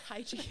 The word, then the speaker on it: hygge: You and I have learned about hygge through the book that you brought me, the little book of hygge, and it's H-Y-G-G-E. So you were pronouncing it hygge, hygge? – Yeah, hygge or something. – Yeah, hygge. Hygge, and it's hygge.